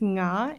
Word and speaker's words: Nga: I probably would start with my name, but I always think like, okay, do I want to say na or nga? Because my name is pronounced nga, N G A, and it's a very hard, you know, sound for if, if, if you don't if you're not used to it.